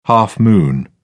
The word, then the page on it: half-moon: British English